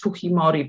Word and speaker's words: Fujimori: Zaraz poprawię na, na, na, na wzór y, y, hiszpański, prawda, bo nam się kojarzy Fujimori, ta wymowa taka bardziej japońska, ten jest Fujimori powiedzmy, że tak jest nazywane.